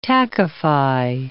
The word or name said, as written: tackify